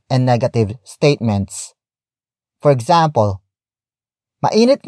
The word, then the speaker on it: mainit: Mainit. Ngayon. Sa. Labas. Ano. Mainit. Ngayon. Sa. Labas. Ano. The first word is mainit.